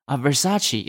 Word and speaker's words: Versace: They will know Versace is called Versace. – Yes, exactly. So you see, if you become a global icon or cultural icon, people will pronounce it your way.